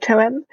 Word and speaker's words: Toem: but it's called Toem. I think it's how you pronounce it, T-O-E-M, and it's just a really chilled,